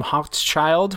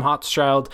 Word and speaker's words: Hochschild: And it is King Leopold's Ghost by Adam Hochschild, which is spelled, because I feel like I've been pronouncing it right, but it's a tough one. It's H-O-C-H-S and then Child, C-H-I-L-D. So Adam Hochschild.